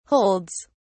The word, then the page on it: holds: British English